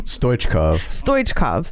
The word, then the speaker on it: Stoichkov: Hristo Stoichkov, and Ronald Koeman. I'm always waiting for you guys to laugh at my pronunciation. – Stoichkov. – Yeah.